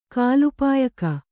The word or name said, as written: ಕಾಲಪಾಯಕ